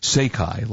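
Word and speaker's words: Seiki: Apparently, the TV is pronounced Seikai, like Seiko.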